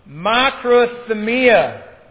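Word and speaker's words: makrothumia (μακροθυμία): Again, the word that is used here in Galatians chapter 5 is a Greek word named, or is pronounced this way, makrothumia.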